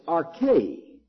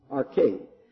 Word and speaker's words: arche: It's arche, A-R-C-H, long E, arche.